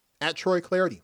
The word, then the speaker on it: Clardy: @TroyClarity, last name is spelled C-L-A-R-D-Y, @TroyClarity.